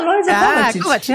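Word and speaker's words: Lojze Kovačič: Oder aber, und jetzt kannst du mir vielleicht auch helfen, damit ich ihn richtig ausspreche, Leutze Kovacic? – Ah, Leutze Kovacic.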